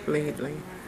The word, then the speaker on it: Laidley: Khadija Levy. – Can you spell it? – K-A-D-A-J-A-H. – L-A-I-D-L-E-Y. Lately. – Mm-hmm. And then your name? – Jacqueline. J-A-C-Q-U-E-L-I-N-E. Gale. G-A-Y-L-E.